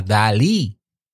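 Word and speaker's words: DALL-E: Pero también está una inteligencia artificial que está abierta al público, que se llama Dalí 2. Como, como Dalí, el, el pintor, pero, pero se escribe D-A LL-E2. 2 ¿Mm?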